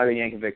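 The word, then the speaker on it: Jankovich: We will see how Yankovic is. I believe that's how you pronounce his game. His right. – name, right? Yeah, that's Tim Yankovic.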